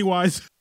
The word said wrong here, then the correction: Weiss: It's actually pronounced Weiss, but go ahead.